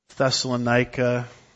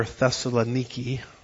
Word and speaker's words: Thessaloniki: In fact, I was just in this area, Corinth, Greece, Thessalonica, that they call over there Thessaloniki, which is always bothersome when you've mispronounced a word your whole life and taught it publicly.